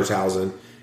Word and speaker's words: Talzin: Well, he grows up on Dathomir as Mother Tazma's... – Talzin. – I can never get her name right.